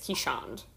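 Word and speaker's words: Keeshond: And I did, like, a Google on how to pronounce it correctly. It's Keyshound.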